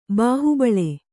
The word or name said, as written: ಬಾಹುಬಲ